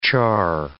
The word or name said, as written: charr